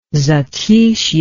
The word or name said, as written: zatishye